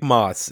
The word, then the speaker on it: Maas: Dick Maas is how I've always heard it Dick pronounced. Maas is, yes.